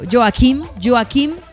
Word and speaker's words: Joachim: Dígale Don Joaquim. Yo le digo yo, pues se escribe Joachim, pero Joaquim Clement.